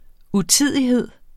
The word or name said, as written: utidighed